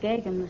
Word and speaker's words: Geoghegan: Miss Edith Geoghegan. I beg your pardon. This must be Stick Godfrey with Tough Names Week. How does that go? – It's pronounced Geoghegan, Mr. Godfrey. – Geoghegan? G-E-O-G-H-E-G-A-N comes out Geoghegan?